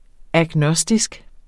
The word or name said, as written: agnostisk